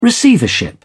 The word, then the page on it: receivership: British English